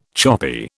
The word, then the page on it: choppy: British English